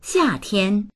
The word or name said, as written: xia4tian1